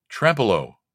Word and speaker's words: Trempealeau: TremPealau. How do you pronounce that? – Trempealeau. – All right. Trempealeau, Wisconsin.